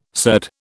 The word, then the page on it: surd: British English